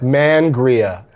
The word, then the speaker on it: Mangria: Mangria. You gotta emphasize the man aspect of it.